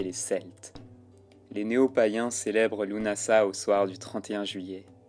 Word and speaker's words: Lughnasadh: Lunasa. Lunasa, écrit parfois Lugnasad, qui se prononce l'unasa ou lamas, fête du pain en vieil anglais.